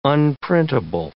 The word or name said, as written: unprintable